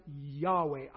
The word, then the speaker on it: Yahweh: That is the word we pronounce, Yahweh.